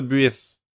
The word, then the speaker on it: Buis: Comment prononcer. Ok, on va trouver ça, là. Comment prononcer Buis? Prononciation de Buis. On écoute le son. – Buis. – Ah, Buis. – Buis. – Buis. – Buis. – Buis. – Buis. – Buis. – Buis. – Buis. – Buis. – Buis. – Buis. – J'entends plus. Fait que Buis.